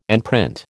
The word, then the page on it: enprint: American English